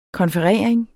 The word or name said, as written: konferering